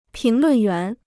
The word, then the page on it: 评论员: píng lùn yuán